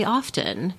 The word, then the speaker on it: often: In this particular discussion of do you say often or often, he was examining how non-native speakers of English are taught to drop that T in the middle, mm-hmm. – but then it's confusing to non-native speakers because they'll hear native English speakers pronounce that hard T.